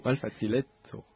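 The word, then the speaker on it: malfacileco: Malfaziletto, avec l'accentuation... sera l'avant-dernière syllabe, enfin normalement.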